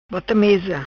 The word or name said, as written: Bota Mesa